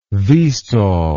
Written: vstol

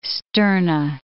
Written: sterna

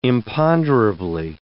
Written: imponderably

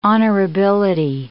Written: honorability

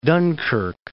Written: Dunkirk

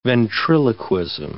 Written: ventriloquism

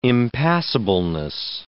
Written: impassableness

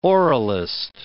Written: oralist

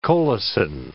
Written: colicin

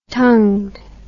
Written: tongued

